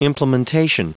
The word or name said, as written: implementation